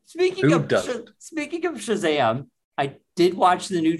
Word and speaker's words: Shazam: Shazam? Is that the proper way? Is that the proper way to Shazam? – That's the proper way to say it.